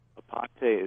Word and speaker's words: Aponte: Aponte is spelled A-P-O-N-T-E.